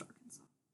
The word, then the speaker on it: Arkansas: Still don't like that it's pronounced Arkansas.